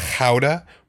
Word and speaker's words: Gouda: Yes, Gouda is actually, you're pronouncing it wrong. It's Gouda with a soft G. – Oh my god. – With a soft G? So you, what does a soft Gouda? G sound like? Just. – Gouda? – Ha. Ha. In the Dutch language, it's ha. It's an H. Ha.